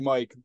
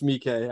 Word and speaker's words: Miike: I used to call him Takashi Miike, but it's Miike. I just don't know how to read. – It's Miike, correct? – Yeah.